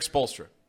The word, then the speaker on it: Spoelstra: Eric Spoelstra.